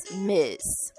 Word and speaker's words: Ms: So this is pronounced as Miss. Okay, but it's MS.